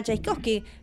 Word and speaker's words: Czajkowski: Tenemos en línea a Hania Czajkowski, se pronuncia así como el compositor ruso.